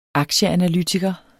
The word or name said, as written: Aktieanalytiker